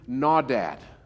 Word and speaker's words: nawdad: The Hebrew word for could not is nawdad. And it's pronounced like that. I looked it up. N-A-W-D-A-D.